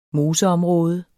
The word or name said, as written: moseområde